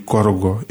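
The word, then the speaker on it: Korhogo: Vous avez dit Korogo, et c'est très bien prononcé.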